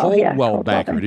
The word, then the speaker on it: Coldwell: Yeah, we kind of pronounce it that way, but it's spelled with C-O-L-D-W-E-L-L.